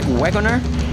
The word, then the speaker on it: Waggoner: Rogers and Paul Waggoner, or do I say it right? Rag Wagoner, like the guy that uh, operates the wagon.